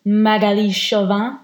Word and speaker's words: Magali Chauvin: And today, obviously hosted by myself, but I'm also joined by the lovely Magali Chauvin. That was my best French accent then. Did I say it right? – Yeah, that was perfect.